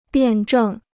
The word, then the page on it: 辩证: biàn zhèng